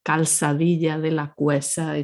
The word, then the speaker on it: Calzadilla de la Cueza: So if you say calzadilla de la cueza, it's fine. Everybody's going to understand you.